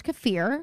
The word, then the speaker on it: kefir: And it is pronounced kefir because I was like,